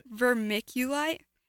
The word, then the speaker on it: vermiculite: Vermiculite.